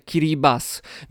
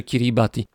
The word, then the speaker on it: Kiribati: to mówimy o kraju, który też może być wymawiany Kiribas, mimo że się pisze Kiribati w języku polskim stosujemy taką nazwę, jaką się pisze, czyli Kiribati. Natomiast w języku Kiribati litery T i, które stoją obok siebie, są wymawiane jako S. Wobec tego jest Kiribas.